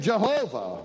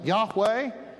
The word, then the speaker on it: Yahweh: But by my name Jehovah, or if we pronounced it as the scholars now think, Yahweh, I was not known to them.